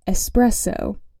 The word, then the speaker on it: espresso: Technically, in relation to coffee, it's pronounced espresso.